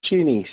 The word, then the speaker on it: Chenny: Chinny, C-H-E-N-N-Y.